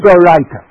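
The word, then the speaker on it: Deoraita: The Kiddush is Doraisa. I've got to pronounce these words correctly. The Kiddush is Doraita, meaning that Min HaTorah, you have to, every Friday night, you have to announce that it's Shabbat.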